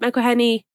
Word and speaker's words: McElhenney: It's just nice to see Rob McElhenney, is that, am I saying his name right? – Yeah.